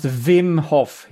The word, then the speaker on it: Wim Hof: And before people correct me, it is spelled with something that looks like a W, but it's actually a double V. So it's pronounced Wim Hof.